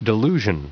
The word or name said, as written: delusion